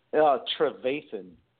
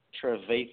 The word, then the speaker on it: Trevathan: It's Trevathan. Trevathan.